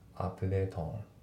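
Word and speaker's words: update on: で、あと発音なんだけど、アップデートとオンのところがリンキングでアップデートオンですね。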